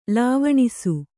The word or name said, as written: ಲವಣಿಸು